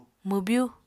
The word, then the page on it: mwbyu: Apatani